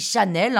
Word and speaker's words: Chasnel: Oh allez, quand même, Coco Chanel, ça devrait vous dire quelque chose alors, Coco Chanel, ou plutôt Gabrielle Chasnel, enfin on dit Chanel, hein, ça s'écrit C-H-A-S-N-E-L normalement, naît le 19 août 1883, c'est-à-dire 100 euh, ans plus tôt que moi, à Saumur.